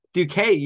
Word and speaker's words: Duquesne: Duquesne University.